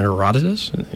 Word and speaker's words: Herodotus: I'm like, Herodotus? He's like, oh yeah, that's how you say it.